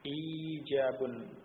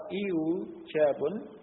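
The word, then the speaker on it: إيجاب: Ijabun. Nah, ini diucapkan orang Arab seperti ini, ijabun. Nah, kalau iujabun tidak diucapkan oleh orang Arab.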